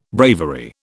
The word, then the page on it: bravery: British English